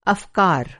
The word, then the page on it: अफ़कार: urdu